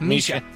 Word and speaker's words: Mischa: It's Misha. – Pronounced Misha.